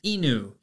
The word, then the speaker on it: inu: Today's lessons is going to be the Japanese word for dog, and that is inu. Inu is spelled I-N-U